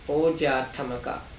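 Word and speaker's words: ojaṭṭhamaka: So in Pali it is Oja Tamaka. five syllables.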